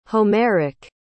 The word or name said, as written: homeric